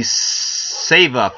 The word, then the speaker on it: SEVA: They're donating their money to the SEVA Foundation. – S-E-V-A. SEVA. – SEVA. It's a long E.